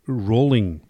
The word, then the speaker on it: Rowling: Just so you know, the name should be pronounced Rowling. – Oh, no kidding. – As in bowling.